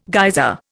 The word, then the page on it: geyser: Indian